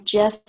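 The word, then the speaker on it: Jeffe: My third and final book of the day is called The Orchid Throne, Forgotten Empire, Book One, by Jeff Kennedy. It comes out on September 24th. So if you are going to look up the book by the author, Jeff is spelled with a silent E.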